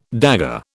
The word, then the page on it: dagger: British English